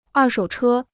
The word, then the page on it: 二手车: èr shǒu chē